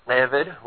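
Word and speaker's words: Eved (עבד): How do you pronounce Eved? Well, it starts with that horrible Hebrew letter Ayin, which means you basically have to start by closing the back of your throat, swallowing and coughing at the same time, and you can approximate the sound of the Hebrew lion.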